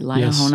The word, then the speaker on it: Liahona: Liahona, and I hope I'm saying that right, Liahona. – Yes, I think that's great.